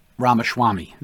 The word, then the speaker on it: Ramaswamy: Washashwami. I can't say it wrong. I can't say it right. And I don't mean to not say it correctly. Vivek Ramaswamy. There you go.